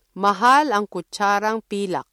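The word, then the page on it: kutsara: Filipino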